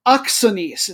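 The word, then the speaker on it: Akranes: Uh, IA Oxanes. It's A K R A N E S, but the pronunciation that I found was very distinct, and it is Oxanes.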